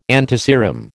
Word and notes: antiserum: American English

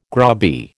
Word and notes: grubby: American English

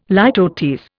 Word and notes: litotes: Indian

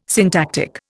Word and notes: syntactic: Indian

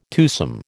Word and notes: twosome: American English

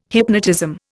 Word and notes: hypnotism: Indian

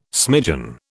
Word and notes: smidgen: British English

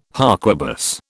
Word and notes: harquebus: British English

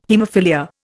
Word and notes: haemophilia: Indian

